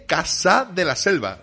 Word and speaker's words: Casa de la Selva: Nada menos que Casa de la Selva. ¿Lo he pronunciado bien? Perfectamente, perfectamente. Ok.